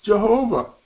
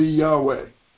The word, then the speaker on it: Yahweh: Or some people would say the proper pronunciation of the Hebrew word would be Yahweh. Sometimes you have the Jehovah people who will fight with the Yahweh people, because the Yahweh people say from the original Hebrew that the word be pronounced Yahweh. The Jehovah people would say, well, that's your way of pronouncing it, but our way is Jehovah.